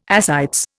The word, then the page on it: ascites: Indian